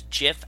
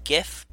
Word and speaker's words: GIF: they pronounce it as GIF, as in Jiffy peanut butter, with a J sound, or like a very soft G, not a hard G like GIF.